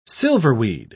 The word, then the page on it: silverweed: British English